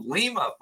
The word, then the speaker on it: Lima: And I am going to jump over to another super chat from Tango Lima Foxtrot. He says it's pronounced Lima, like I said Lima a second ago.